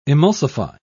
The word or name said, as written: emulsify